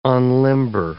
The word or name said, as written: unlimber